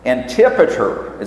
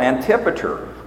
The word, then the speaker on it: Antipater: Antipater. It's like anti-father. Antipater.